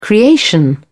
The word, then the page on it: creation: British English